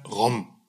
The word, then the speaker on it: Rhum: Und es ist der Rom. Also ich habe schon gelernt, so wie CD-ROM, so kann man das aussprechen, ROM.